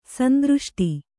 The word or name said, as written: ಸಂದೃಷ್ಟಿ